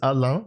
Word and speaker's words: Alain: So the two swords are, one of them is Alain. I'm gonna use the French pronunciation here.